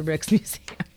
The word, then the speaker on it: Rijksmuseum: Is it Rick's Museum? Yeah, okay. – It's just Rick. It's Rick's Museum. – Okay, it's a Rick's Museum.